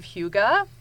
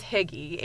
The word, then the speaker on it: hygge: So if, I don't know if you know, have ever heard of the concept of hygge. – No. – Sometimes it's mispronounced as hygge, H-Y-G-G-E.